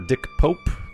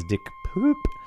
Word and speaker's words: Dick Pope: Best Cinematography for Dick Pope, which was funnily mispronounced during the announcements as Dick Poop.